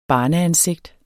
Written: barneansigt

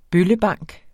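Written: BØLLEBANK

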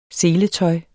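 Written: SELETØJ

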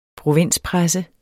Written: provinspresse

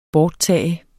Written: borttage

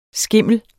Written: skimmel

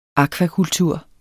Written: AKVAKULTUR

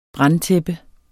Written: brandtæppe

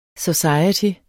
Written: society

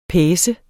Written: PACENDE

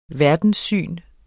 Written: verdenssyn

